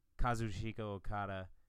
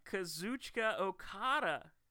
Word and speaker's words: Kazuchika Okada: Kazuchika Okada. – Kazuchika. No, I'm pretty sure in Japanese it's just you read every syllable with no emphasis.